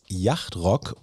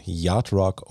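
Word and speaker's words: Yacht Rock: Geschrieben wird das Yachtrock und diesen Begriff haben vielleicht auch einige Hörerinnen und Hörer schon mal irgendwo gelesen. Die korrekte englische Aussprache ist aber eben Yachtrock und als gelernter Englischlehrer möchte ich das natürlich auch gern richtig aussprechen.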